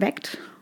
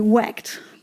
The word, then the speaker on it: VACT: Und um ihn zu finden, könnt ihr VACT, ausgesprochen WACT, wie WACT.